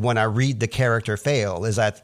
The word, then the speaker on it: Faile: It's a character called Fail. Her name is spelled F-A-I-L-E, and I've always just read it as Fail. Some people might say Fail-E, but this is a key character that we're really surprised to see this soon in the story.